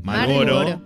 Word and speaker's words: Marlboro: Malboro le dice. – Saca que. – Es como que no pronuncias la R en realidad. – Claro. – Malboro.